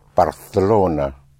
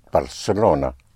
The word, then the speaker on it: Barcelona: where the C in front of an E or I is pronounced like a TH in English, like in Barcelona. That's how you will pronounce it in Castilian Spanish in parts of Spain, but everywhere else it will be pronounced Barcelona, like an S.